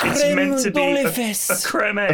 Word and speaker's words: Creme Egg: I just saw the Cadbury's Twitter had replied that cream egg is actually pronounced creme egg.